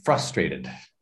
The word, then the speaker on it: frustrated: It'd be frustrated. – Frustrated. – Yeah. So the intonation is the accent on the first syllable.